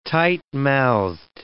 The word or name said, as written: tight-mouthed